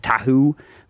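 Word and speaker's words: Tahou: Meat sauce from the famous meat sauce place called Nick Tahoe. Not spelled Tahoe. Spelled like Tahoe.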